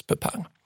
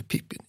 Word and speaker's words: Pippin: Alltså vänner sig många vid att tro att uttalas Pepin. Ända fram till Sagan om ringen, då Tolkien ger namnet en ny renässans. Och då börjar vi kalla det Pippin igen.